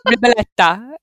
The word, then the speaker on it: ribollita: R I B Riboletto. O-L-L-I-T-A. Riboletta.